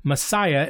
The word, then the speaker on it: Massaya: The first wine we're going to drink today is the 2021 Masaya. It's spelled M-A-S-S-A-Y-A, Les Colombières Rouges.